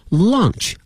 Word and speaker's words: launch: 推出这个词 launch 啊，大家一定要和午饭这个 lunch 分开了， launch L-A-U-N-C-H，午饭呢是 lunch L-U-N-C-H，所以大家这两个词啊不要把午饭念成了 lunch，也不要把我们说推出念成了 lunch，这两个词的发音有一定的相似的地方，但是呢是两个完全不同的两个单词。